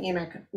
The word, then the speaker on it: emic: So I think it's pronounced edic and emic, 99% sure.